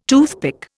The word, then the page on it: toothpick: Indian